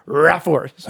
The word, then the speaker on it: Raaphorst: I'm going to say his name is Raforst. – How do you spell it? – R-A-A-P-H-O-R-S-T. – Yeah. – Raforst. – Raforst.